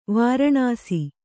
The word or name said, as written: ವಾರಾಣಸಿ